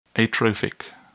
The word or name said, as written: atrophic